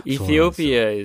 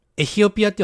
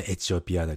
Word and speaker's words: Ethiopia: Ethiopia is special.、エヒオピアっていう発音なんだね。日本語ではね、エチオピアだけど、エヒオピア。